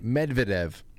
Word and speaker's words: Medvedev: Medvedev. How do you say his name? – Medvedev. – Yeah, thank you.